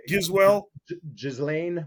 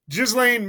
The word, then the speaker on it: Ghislaine: Ghislaine Maxwell. – Ghislaine Maxwell.